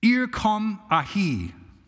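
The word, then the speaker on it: ir kom ahi (ἔρχομαι): The I came in that verse, it's Greek pronunciation and word is ir kom ahi.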